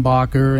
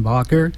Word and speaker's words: Bacher: Go to the internet and just Google search Dan Bakker, and his last name is spelled B-A-C-H-E-R, Dan Bakker,